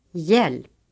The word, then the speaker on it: hjälp: The letter J is sometimes preceded by another consonant in the writing system. In such cases, the other letter is always silent. Examples. – Jude. Djur. Hjälp. Ljus.